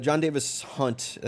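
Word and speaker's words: Jon Davis-Hunt: Sean, John Davis Hunt, that's his name, right? Am I saying that correctly? – Yeah, yeah.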